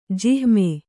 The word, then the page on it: ಜಿಹ್ಮ: jihma